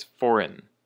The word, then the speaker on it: foreign: Our next word is foreign. Foreign, and the emphasis is on the first syllable, on the for, foreign.